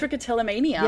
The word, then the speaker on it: trichotillomania: Trichotillomania.